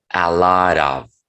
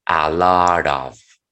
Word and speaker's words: a lot of: Recuerden que les he comentado, cuando la T después tenga un sonido vocálico, pronúncienla como el sonido de R que tenemos en la palabra barato, ¿ok? Entonces digan a lot of, a lot of, a lot of.